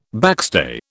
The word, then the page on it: backstay: British English